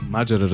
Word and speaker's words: Маджера: Абе, верно ли Маджера е правилното ударение? – Разбира се. – Маджера, разбира се.